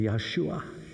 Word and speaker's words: Yahshua: How do you say the name Jesus in Hebrew? Joshua, or more specifically, Yahshua.